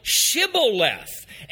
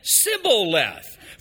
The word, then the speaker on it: Shibboleth: And then they said unto him, "Say now, Shibboleth." And he said, "Sibboleth." For he was not able to frame to pronounce it right.